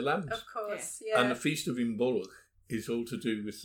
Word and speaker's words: Imbolc: It's spelled I-M-B-O-L-C or O-L-G. But when you say an Irish word, if it's got a G, if it's got a consonant at the end, you sound that letter. So it looks like Imbolc or Imbolc, but it's Imbolc.